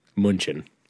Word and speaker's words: München: That's actually the German pronunciation, and that's how he wrote it. I'm not being a cock. München.